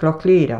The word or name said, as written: KNOCKLEAROCH